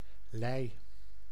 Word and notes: abri: a.bʁi; France

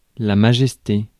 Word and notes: majesté: France; [ma.ʒɛs.te]

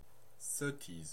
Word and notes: sottise: France (Normandie); /sɔ.tiz/

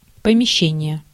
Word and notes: помещение: /pəmʲɪˈɕːenʲɪjə/

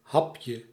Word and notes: bit: /bɪt/; [bɪt]

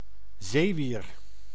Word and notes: zeewier: /ˈzeːˌʋiːr/

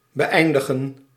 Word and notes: conclude: American English; /kən.ˈkluːd/

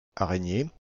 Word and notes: spider: American English; [ˈspaɪ.dɚ]

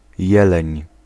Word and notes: chevreuil: France; /ʃə.vʁœj/

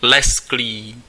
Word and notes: brillant: [bʁi.jɑ̃]; France

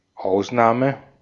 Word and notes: exception: [ɪkˈsɛp.ʃən]; American English

